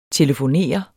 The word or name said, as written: TELEFONERER